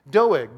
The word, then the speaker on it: Doeg: Doeg. But he stuck out to me because, and it's like dough, like cookie dough, and egg, like, egg. Okay, dough, egg, Doeg.